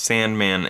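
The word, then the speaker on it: S&Man: The movie we're talking about, is the reason there's a little confusion is because it's spelled S&, M-A-N. – Yeah. – And it's pronounced Sandman, and it's this really bizarre.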